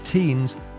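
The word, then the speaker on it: Theuns: Theans is pronounced Teens and Botha is pronounced Botha. They are both Afrikaans names. We don't normally pronounce the H and we have hard T's.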